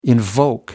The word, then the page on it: invoke: American English